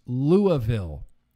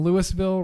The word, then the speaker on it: Louisville: I cannot allow my husband to pronounce Louisville incorrectly. It's the name of bourbon. It's pronounced Louisville.